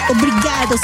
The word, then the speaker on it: obrigado: Obrigado significa gracias. – Buenas noches. – Porque van a pensar que estás diciendo obligado. – No, obrigado.